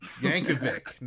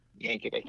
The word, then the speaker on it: Yankovic: No, no H at the end there. – Yankovic. – Yes. – Yankovic. – That's how you say it. All these years I've been putting an itch on it. – It's a common mistake.